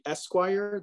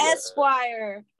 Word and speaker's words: Esquire: How do you pronounce that? – Esquire. That's a the, Esquire. Okay, Esquire. Excuse me.